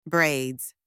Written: braids

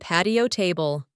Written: patio table

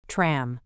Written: tram

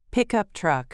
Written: pickup truck

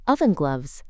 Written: oven gloves

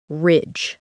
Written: ridge

